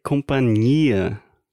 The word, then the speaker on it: companhia: Então, o companhia que a gente está falando em questão se escreve C-O-M de Maria, P-A-N-H-I-A. Né? Então, esse NH no meio deveria ser companhia é.